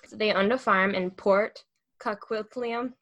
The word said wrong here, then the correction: Coquitlam: that's not pronounced correctly, British Columbia. Huh? It's Coquitlam.